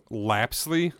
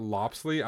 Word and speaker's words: Lapsley: Other than, okay, so technically her name is Lapsley, not Lopsley. I've been pronouncing it with the accent.